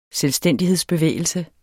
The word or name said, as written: selvstændighedsbevægelse